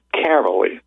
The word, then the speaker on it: Caverly: And you can write to me at Lou Caverly. Now, you spell that name as C-A-V, as in Victor, E-R-L-Y.